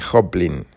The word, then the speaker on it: Joplin: Se escribe J-O-P-L-I-N, Hoplin, ¿sí?